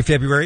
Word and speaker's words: February: There's a right way to say February and there's a wrong way to say February. It's a silent R. It's without the R.